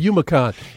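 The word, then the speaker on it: Youmacon: Well, just look at it just the way it's spelled. It's Y-O-U-M-A-C-O-N. – Okay. – HumanCon. It's called HumanCon.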